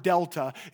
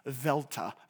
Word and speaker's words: delta: See, the word, the letter delta, we've anglicized these letters in Greek, but the word delta is not pronounced delta. If you used it in Koine Greek, it would be pronounced velta, right? That's how it's pronounced.